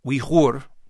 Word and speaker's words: Uyghur: And their name, although it's spelled various ways, would be pronounced Uyghur in their own Central Asian language and is best spelled U-Y-G-H-U-R.